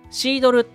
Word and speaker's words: CDLE: シードルっていうのは CDLE と書いてね、シードルって読むんですけど、